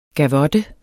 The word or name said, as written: gavotte